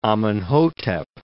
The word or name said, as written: Amenhotep